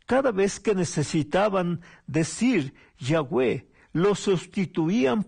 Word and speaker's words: Yahweh: Cuando se agregan las vocales se pronuncia Yahweh, que en español sería Jehová.